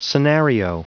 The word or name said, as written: scenario